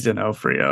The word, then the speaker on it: D'Onofrio: And then someone tweeted at Kingpin actor Vincent D'Onofrio. Probably mispronounced that, Vincent. I'm sorry. Come on here and tell me I did it wrong. – I think it's D'Onofrio. I don't think there's the R in there.